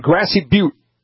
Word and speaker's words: Grassy Butte: Let's go to Gator Site in Grassy Butte. Am I saying that right? – Yes, you are.